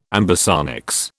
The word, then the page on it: ambisonics: British English